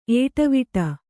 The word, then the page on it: ಏಟವೀಟ: ēṭavīṭa